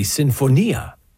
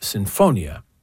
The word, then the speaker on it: sinfonia: So the author of this letter said that as an Italian speaker, he found it grating when he heard me say sinfonia, because in Italian that word, which is an Italian word, would be sinfonia. Now, in this case, this composer, Mariana Martinez, lived in Vienna. So there it would be pronounced sinfonia, or something closer to that.